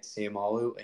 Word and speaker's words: Seumalo: Yeah, and the latest addition you mentioned too, Isaac Seumalo. I think that's how you pronounce his name. I watched a video of him saying it, Seumalo, and he says it really quick.